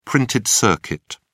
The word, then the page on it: printed circuit: British English